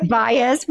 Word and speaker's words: Baez: And my guest today, her name is Shirley Baez. Did I say that right? Baez? – Baez, yeah. – Baez.